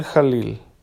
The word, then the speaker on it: Khalil: Padre Samir Jalil. ¿sí? Samir Khalil, aunque me parece que se escribe con K. Sí, con K-H, K-H-A-L-I-L.